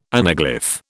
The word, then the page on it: anaglyph: British English